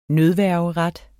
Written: nødværgeret

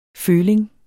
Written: føling

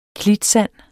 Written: klitsand

